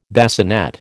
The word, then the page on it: bassinet: American English